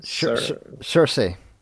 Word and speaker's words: Saoirse: Saoirse Ronan? – Cersei. – Not like Game of Thrones.